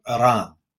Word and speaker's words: Iran: You got to start pronouncing Iran the right way. It's Iran. Okay, get it right.